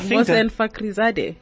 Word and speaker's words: Mohsen Fakhrizadeh: Mohsen Fakhrizadeh.